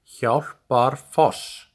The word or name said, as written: Hjálparfoss